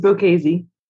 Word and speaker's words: Bocchese: It's Bocchese.